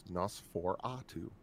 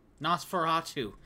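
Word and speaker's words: NOS4A2: One that we just recently started watching the show is a show called NOS 4A2, which is a license plate number, but if you pronounce it, it's Nosferatu. – Nosferatu, like the vampire.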